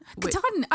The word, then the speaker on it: Katahdin: Katahdin. I've done Katahdin.